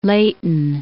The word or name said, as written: Leighton